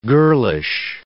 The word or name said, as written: girlish